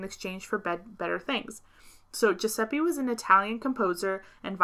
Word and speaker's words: Giuseppe: The first one is, and I mean, I think this is pronounced Giuseppe. – Yep. – Giuseppe!